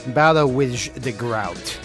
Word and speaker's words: Boudewijn de Groot: The band again is Boudewijn de Groot. – Okay, and for those people who... – Boudewijn Groot. – All right, you spell that B-O-U-D-E-W-I-J-H space D-E space G-R-O-U-T. So it's pronounced Boudewijn de Groot.